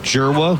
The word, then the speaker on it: Dzierwa: Joseph Jerwa, whose last name is D-Z-I-E-R-W-A, pronounced Jerwa, he deals.